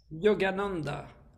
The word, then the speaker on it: Yogananda: Yogananda. – Yes. – Yogananda, the name of the show is Yoganandance. It's a musical to celebrate Yogananda, the famous Indian guru. Mm-hmm. Yogananda was the first who came from India to America in 1921.